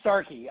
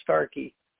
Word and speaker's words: Starke: It's Andrew Starkey. I've been called Stark since kindergarten or preschool, but it is pronounced Starkey.